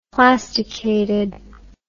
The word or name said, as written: plasticated